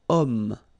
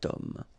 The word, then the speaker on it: homme: Remember, ash is not pronounced, okay, so you've got the sound of o at the beginning of this word, okay, cet homme.